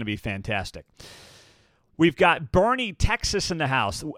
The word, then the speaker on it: Boerne: And thank you once again for the pronunciation guides that you are providing here on the show, because Bernie, Texas, B-O-E-R-N-E, is not how I would have pronounced it, but we appreciate you telling us how to pronounce it in the pronunciation guide.